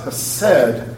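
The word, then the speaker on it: hased (חסד): And this word pronounced in Hebrew is hased.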